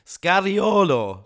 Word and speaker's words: Scariolo: Scariolo. – Scariolo. Nicht Scarolo.